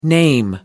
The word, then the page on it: name: American English